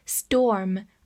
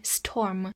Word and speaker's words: storm: Storm。 S T O R M，storm，O R 字母组合发长音 o。另外注意一下，T 放在了 S 的后面会浊化，读作 storm 而不是 storm。